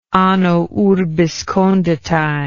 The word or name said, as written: anno urbis conditae